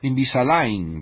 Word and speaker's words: Invisalign: Invisalign, pronunciado Invisalign. Así, claro, está escrito de esa otra manera, y a nivel popular no sé cómo lo dirán.